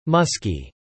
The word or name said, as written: musky